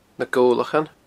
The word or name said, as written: Gobhlachan